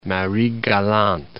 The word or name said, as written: Marie Galante